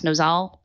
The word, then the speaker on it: Nosal: versus, I think it's pronounced Nosal, I may be wrong on that, N-O-S-A-L.